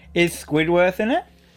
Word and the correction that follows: Squidward: Squidward. Yeah. – Squidward? – Squidward, yeah. You said it right. Him. I said it wrong.